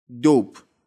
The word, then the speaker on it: dope: D O P E تو گویش آمیانه دوپ هم یعنی مواد مخدر یه نکته تلفظی هم بگم دوپ دقت کنید دوپ نگفتم دوپ چرا چون آخرش حرف ای داره اسپل کردم دیگه دی او پی ای وقتی این شکلی میشه کلمه رو یه ذره کشیده تر تلفظ میکنیم